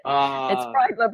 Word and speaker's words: Bridler: It's Bridler, but it doesn't matter.